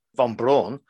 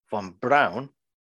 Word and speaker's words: Von Braun: Characters in the opening scene pronounce the name of the transport ship as Von Braun, to rhyme with drawn, rather than Von Braun, that would rhyme with down, which it should be.